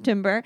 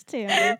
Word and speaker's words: timbre: Because I need to know because I've definitely used this word and would have pronounced it timbre in my writing. – It's timbre.